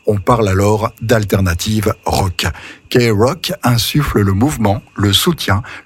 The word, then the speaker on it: KROQ: Son call letters, K-R-O-Q, son indicatif, si vous préférez, s'efface alors au profit de sa prononciation, qui colle si bien alors à sa couleur musicale. Elle devient K-Rock.